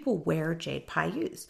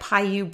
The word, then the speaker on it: Pixiu: And so if you Google like Paiyu bracelet, it's spelled P-I-X-I-U.